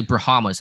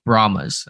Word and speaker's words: Brahmas: Brahmas? – Yeah, I always want to say Brahmas and put an extra A in there, but I guess it's just Brahmas.